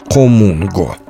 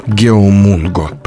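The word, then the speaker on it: хомунго (거문고): Одно время меня очень удивляло, как пишется латиницей название корейского традиционного музыкального инструмента «хомунго» – «геомунго». Если прочесть так, как на основных европейских языках, то будет, как я уже сказал, «геомунго» – почти что осмысленный текст.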